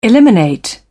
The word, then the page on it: eliminate: British English